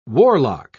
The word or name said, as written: warlock